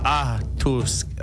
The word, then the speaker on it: Atoske: Atoske. Oh my gosh, my pronunciation is so bad. Atoske. So it's ato. So not atoske.